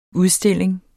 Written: udstilling

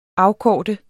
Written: afkorte

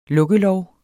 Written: lukkelov

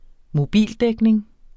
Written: mobildækning

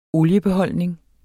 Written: oliebeholdning

